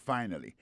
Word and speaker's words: finally: Alors, t'aimes plus finally à pour nous, finally, c'est F-I-N-A-L-L-Y, finally. T'as des prononciations, finally. Accent tombé sur première syllabe là. Finally, F-I-N-A-L-L-Y, ça veut dire en bon créole, enfin, séraphin, pied fin, manger à réussir, arriver sous table là.